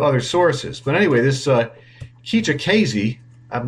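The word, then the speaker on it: Kijakazi: The report said at a congressional hearing earlier this year, SSA Commissioner Keija Kazi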